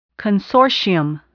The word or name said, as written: consortium